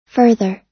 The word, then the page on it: further: American English